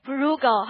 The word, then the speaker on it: frugal: 跟节省有关的叫做节俭是哪个单词啊？Frugal 哈，记得看到发音 Frugal 哈，俘虏就够了。